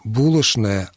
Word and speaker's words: булочное: Произношение «скучно», «булошное» абсолютно нормативно, допускается, и более того, оно является, во всяком случае, именно для этих слов, равно как и для слова «конечно», к примеру, является более предпочтительным, чем произношение звука «ч» на месте буквы «ч».